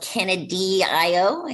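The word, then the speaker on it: Canadee-I-O: And you were on a compilation where you played an English folk ballad called Canadio. I guess it's, I don't know if that's the right way to pronounce it. – That's perfect. No, it's a perfect way.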